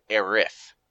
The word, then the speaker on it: Aerith: Actually, the correct translation is actually Aerith. T-H instead of S.